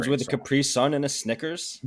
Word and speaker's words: Capri Sun: Capri Sun. So the emphasis is on the sun. – Well, the emphasis is on the last syllable.